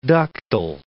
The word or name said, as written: ductile